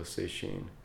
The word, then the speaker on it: Sesshin: But instead it's called Sesshin, which is two characters together. The first is pronounced Setsu. And the second one is Shin. And when you glom them together, changes the pronunciation, like the most compound words. So Sesshin.